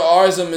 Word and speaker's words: Arsham: Daniel Arsham is doing, I don't know how to pronounce that nigga's last name. – I think it's like Arsham.